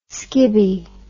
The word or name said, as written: skibby